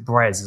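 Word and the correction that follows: Brēz: as well. I don't know why, but yeah. Breeze. I'm glad you clarified that.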